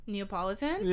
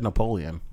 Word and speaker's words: Neapolitan: Neapolitan? – Yeah. He's going to have, like... – Is that how you pronounce it? – Yeah. – I always said Napoleon.